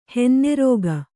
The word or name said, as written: ಹೊನ್ನರಿಕೆ